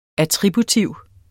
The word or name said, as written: attributiv